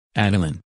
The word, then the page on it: Adalyn: English